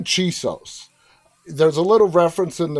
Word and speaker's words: Chisos: Well, his name is Chisos, C H I S O S.